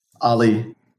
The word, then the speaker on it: Ali: Ali. – Ollie? Like, actually? – Yeah. A-L-I,